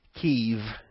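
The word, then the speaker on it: Kyiv: or as the locals pronounce it, Kiev. It's a one-syllable, long E-sounding word, Kiev.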